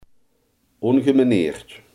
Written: ongemanierd

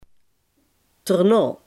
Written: erna

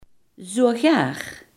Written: zelfs

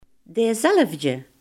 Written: diezelfde

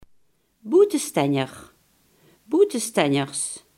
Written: buitenstaander

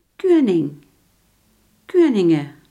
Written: koning

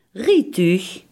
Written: rijtuig

